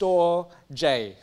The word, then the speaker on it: storge: The second one in that list is it looks like storge, but it's pronounced storge. Storge. Say with me, storge. If you know that the popular music shop Store DJ, just take out a D, Store J.